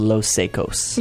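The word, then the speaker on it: Los Saicos: I think it's pronounced Los Secos.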